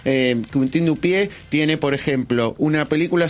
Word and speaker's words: Quentin Dupieux: La primera de ellas es de Quentin Dupieux, así se pronuncia.